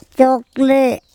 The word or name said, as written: cOkle